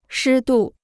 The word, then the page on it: 湿度: shī dù